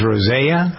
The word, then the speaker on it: rosea: Rhodiola rose, is it pronounced rosea? How do you pronounce that? – Well, yes, rhodiola rosea.